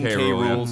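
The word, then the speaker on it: K. Rool: King K, is it K Rule? Is that the official like pronunciation? – King K Rule.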